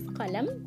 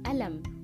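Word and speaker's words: قلم: in egyptian we say alam we pronounce qaf like ah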